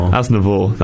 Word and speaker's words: Aznavour: How do you pronounce his name? – Aznavour.